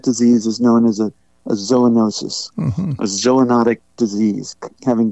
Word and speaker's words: zoonosis: And the technical biological term is zoonosis. Am I pronouncing that correctly? – That's right. That's right.